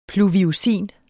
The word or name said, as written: PLUVIUSIN